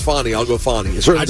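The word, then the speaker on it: Fani: The way she pronounces it, Fonnie. But most people just call her Fanny.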